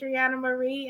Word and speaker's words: Adreannia Marie: I want you to help me welcome to the show Adreannia Marie, and I hope I'm putting all the phonetically correct pronunciations on that beautiful name. – Thank you so much, Miss Christie. It's Adreannia Marie, and I'm so happy to be here, and I appreciate the opportunity.